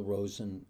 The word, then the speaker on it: Rosen: Michael Rosen, and the last name is spelled R-O-S-E-N.